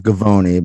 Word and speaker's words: Gavoni: Gavoni. Gavoni. Rhymes with bologna, macaroni.